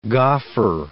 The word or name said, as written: goffer